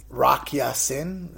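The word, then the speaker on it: Rock Ya-Sin: Rock Yasin, is that how you say it? The kid out of Temple? – Yep.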